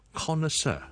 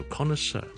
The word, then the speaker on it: connoisseur: 咁啊，当然萧叔讲嘅发音咧就系英文嘅发音，connoisseur。你可以当个两个重音，第一个重音 con，第二个重音是 sir，做 connoisseur，就是一个非常识食嘅人。